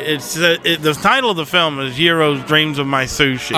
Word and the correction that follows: Jiro: You're giving it some like weird Latin pronunciation. He's Japanese. It's Jiro.